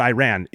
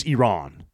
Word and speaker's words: Iran: And he corrected him at first by saying, you're pronouncing the country wrong. It's not Iran. It's Iran.